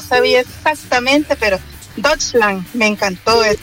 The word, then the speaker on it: Deutschland: Pero si sí le interesa, se pronuncia Deutschland. Deutschland. Eso.